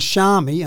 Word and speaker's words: Xiaomi: And Xiaomi, and I, I never know if I pronounce that right, X I A Oh, Sounds right to me.